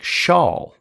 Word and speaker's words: Shaul: Saul's name would be pronounced more like Shaul, with an SH.